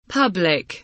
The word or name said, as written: public